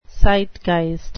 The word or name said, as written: zeitgeist